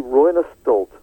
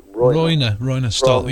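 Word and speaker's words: Roine Stolt: But it's going to be Royner Stolt. That's how to pronounce it. – Royner Stolt, yeah.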